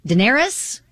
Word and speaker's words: Daenerys: One of my girlfriends went as Daenerys from Game of Thrones.